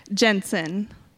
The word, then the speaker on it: Jensen: Jensen.